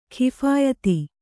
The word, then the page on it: ಕೈಫಿಯತ್ತು: kaiphiyattu